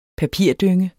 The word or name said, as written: papirdynge